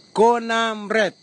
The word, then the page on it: konambre: kwamera; Tanna